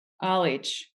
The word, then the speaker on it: Alić: As a Croatian, your name is pronounced Nada Alić, right? – Alić. Yeah. – Yeah. I heard like Alec on some podcast. – Yeah. Well, I guess that's the American way to say it.